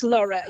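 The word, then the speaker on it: Lorrha: Well, joins us now from her family farm at Lorha. Have I got the pronunciation right, in Tipperary? – It's Laura, yes. – Laura. – Ian Laura, yes.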